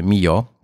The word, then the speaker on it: Millau: Tam y, bardzo blisko właśnie miejsca, w którym nocowaliśmy jest najwyższy wiadukt Europy, wiadukt Mio pisze się Millau przez 2L.